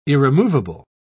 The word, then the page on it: irremovable: British English